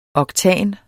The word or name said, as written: oktan